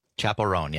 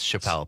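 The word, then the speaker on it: Chappell Roan: I would have also guessed Chappelle based on how it's spelled.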